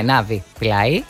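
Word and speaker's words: Navi Pillay: Το όνομά της είναι Ναβαντέμπτ, αν το προφέρω σωστά, Πιλάι. – Ναι, Ναβι, Πιλάι.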